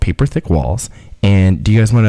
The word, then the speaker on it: PaperThickWalls: That was a lovely song by PaperThickWalls.